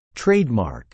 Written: trademark

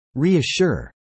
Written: reassure

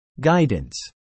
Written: guidance

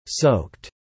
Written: soaked

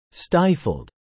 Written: stifled

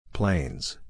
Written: planes

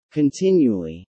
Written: continually

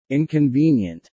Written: inconvenient

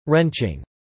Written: wrenching

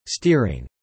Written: steering

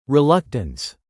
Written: reluctance

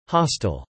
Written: hostel

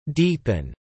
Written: deepen

